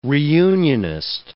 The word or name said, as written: reunionist